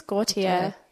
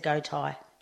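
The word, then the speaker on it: Gotye: Is that how you pronounce her name? – Goite. – I think it's Gautier. – Gautier? – Oh, okay. I would have said Gautai.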